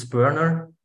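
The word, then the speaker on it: Börner: It's Burner. It's like in America, burning wood. You know, this is how you pronounce it. As being said, my name is Christoph Burner.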